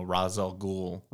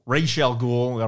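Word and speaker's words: Ra's al Ghul: Uh, you know Razal Ghul, Rachel Ghul.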